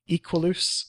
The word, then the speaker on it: Equuleus: Equalus, E-Q-U-U-L-E-U-S, Equalus. I guess because it's the two U's say, it, to me, it says pronounced like I'm Latin, so Equalus.